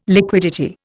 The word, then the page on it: liquidity: Indian